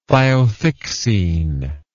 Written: thiothixene

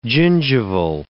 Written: gingival

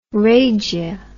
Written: rrhagia

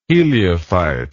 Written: heliophyte

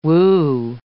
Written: woo

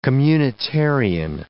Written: communitarian

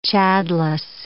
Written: chadless

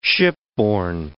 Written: shipborne

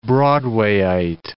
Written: Broadwayite

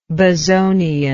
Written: bezonian

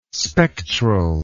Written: spectral

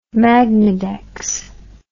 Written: magnedex

